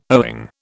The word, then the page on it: owing: British English